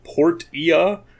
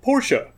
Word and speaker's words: Portia: It looks like it's spelled Portia, but it's called Portia.